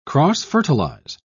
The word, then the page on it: cross-fertilize: British English